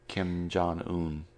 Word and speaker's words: Kim Jong-un: Kim Jong, how do you say his name? The North Korea? – I don't know. Kim Jong-lee, I think. – Kim Jong-un.